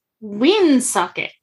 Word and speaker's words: Woonsocket: It's pronounced Woonsocket.